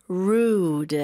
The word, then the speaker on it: rude: Rude. Mm-hmm. 네, rude. R 발음 확실히 해주셔야 돼요. 혀가 천장을 닿지 않아요.